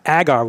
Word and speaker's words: agar: Number two is agar wood, which I don't know if I'm pronouncing it right. It's A-G-A-R. I mean, that's seems good. – Straightforward as you can get.